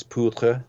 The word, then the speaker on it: Poutre: P-O-U-T-R-E.